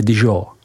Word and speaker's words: Dijon: a jižní hranici město Dijon, vyslovujeme Dijon.